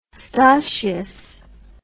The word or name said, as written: stoshious